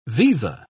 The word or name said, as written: visa